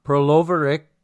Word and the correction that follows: Pervolarakis: Wait. Pervolakoris and John Millman for Australia. Man, I stumble over that young kid's name every single time. Mikhail, I am so sorry. I cannot say his name. Pervolarakis. Thank you.